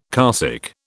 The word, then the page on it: carsick: British English